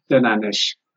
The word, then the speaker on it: denanesh: But what about and there are two other words I learned 2 weeks ago when we met: denane and denache. Did I pronounce denane? Denanesh. – Denane, denanesh. Okay, yes. not denache. Denanesh.